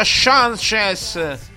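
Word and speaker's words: Sanches: si pronuncia Sanchez. – Sì, alla, alla, portoghese, alla brasile, no, alla portoghese, alla portoghese.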